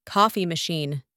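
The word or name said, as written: coffee machine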